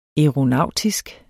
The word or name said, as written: aeronautisk